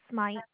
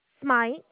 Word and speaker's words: Smyte: It's Aya, and the last name is Smite. – How do you spell Aya? Tell me how you spell that. – A-I-Y-A. – I-A-Y-A. And what is your last name? – Smite. – How do you spell that? – S-M-Y-T-E. – S-Y-M-T-E.